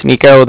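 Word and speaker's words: Niko: Okay, like I said, my name's Nico. That's N-I-K-O. And then calling from the Bank of Cardiff, and that's spelled C-A-R-D-I-F-F, as in Frank.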